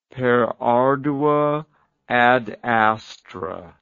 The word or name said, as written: per ardua ad astra